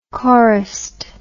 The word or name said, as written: chorist